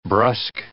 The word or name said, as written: brusk